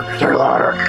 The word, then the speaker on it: Delauter: I think it rhymes with slaughter. Kirby Delauter. – Delauter.